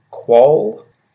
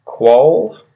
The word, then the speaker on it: quoll: The next one is quoll. Quoll. Quoll, which is spelt Q-U-O-L-L.